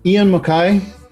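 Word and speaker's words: Ian MacKaye: Ian MacKay, did I pronounce that right? – That's correct.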